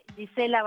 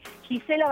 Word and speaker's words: Gisela: En mi canal, en el canal que lleva mi nombre, Gisela Barreto, se escribe G-I-S-E, una sola L-A. Y Barreto, mi apellido, con B alta, B larga, A-R-E-T-O, una sola T. Gisela Barreto,